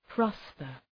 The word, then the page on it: prosper: ‘prɒspər